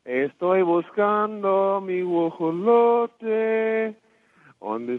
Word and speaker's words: Guajolote: Guajolote.